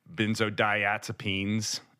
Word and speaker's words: benzodiazepines: Benzodiazepines.